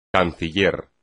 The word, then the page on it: canciller: espagnol